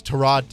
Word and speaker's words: Tyrod: Everybody says Tyrod Taylor as Tyrod Taylor, but he had said that, no, it's Tyrod Taylor.